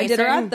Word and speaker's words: Iditarod: Yeah, this is the one where you are, and Iditarod, is that how you say it? – Yeah, Iditarod.